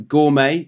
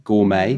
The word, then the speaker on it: gourmet: Next one, gourmet. Do you want me to just pronounce them? – Mm-hmm. – Gourmet. That's a good one. Mm-hmm. Silent T there. Gourmet.